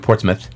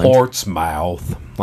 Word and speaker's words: Portsmouth: By the way, he was born in Portsmouth, as we've learned how to pronounce. – Portsmouth, yes. – From one of our dear friends in England. Not Portsmouth, like we said it before.